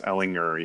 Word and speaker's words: Ellinger: Yeah, it's a hard R. It's Ellinger, yeah. Or a hard G, I should say.